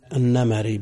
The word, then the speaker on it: النمري: النمري بفتح الميم. النمري كل ما كان مكسور الثاني تفتح في النسبة، مثل سلمة سلمي، ملك ملكي، لأنه في الأخير بدأوا ينطقون صاحب السمو الملكي وكذا.